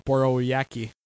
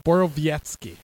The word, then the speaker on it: Borowiecki: I can't pronounce his name. – Borowiecki. – Borowiecki. – Yep. – That's a tough one for me to pronounce. Your thoughts on the hit? – The W is a V, essentially.